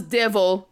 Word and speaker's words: de Vil: Or what used to be, well, is spelt as devil, but it's pronounced devil.